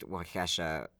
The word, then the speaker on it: Waukesha: So, as is the tradition, is pronounced Waukesha, Wisconsin.